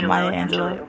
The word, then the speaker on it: Maya Angelou: Now, I could be saying her name wrong, so yeah, Maya Angelou.